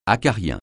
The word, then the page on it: acarien: francais